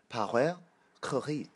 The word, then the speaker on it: Paweł Kukiz: You're 100% on that pronunciation? – And you want to give it a go? – I don't. – Paweł Kukiz? – It's Paweł Kukiz. – That sounded right.